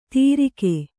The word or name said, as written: ತಿರಿಕೆ